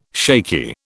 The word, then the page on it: shaky: British English